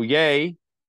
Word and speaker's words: Ye: So Ye, who is a billionaire, by the way. – I would pronounce that Ye.